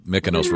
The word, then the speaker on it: Mykonos: Yeah, Mykonos rhymes with agnostic.